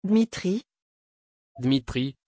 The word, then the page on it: Dmitri: French